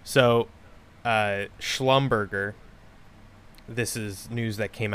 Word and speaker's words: Schlumberger: That's yeah, they're like, yeah, it's pronounced Schlumberger.